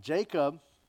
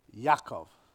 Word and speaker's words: Yaakov: Jacob is not how it would be pronounced in the Hebrew. You know how often I like to pronounce them by their Hebrew names? So it's not Jacob. It would be Yaakov.